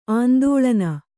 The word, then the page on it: ಆಂದೋಳನ: kannada